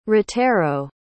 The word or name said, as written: ratero